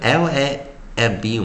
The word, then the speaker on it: lamb: l 在字字首的时候，在首音的时候，我们是念 l，l，l，所以 l a m b 我们念 lamb，lamb，lamb。再一遍啊，l a m b，我们念 lamb，lamb，lamb，哦，不要把那个 b，哦，不要把 b 给念出来啊，silent b。所以我们今天教到就是 a m b lamb，am，am，lamb，lamb，关闭音节 short vowel，而且 b 不发音。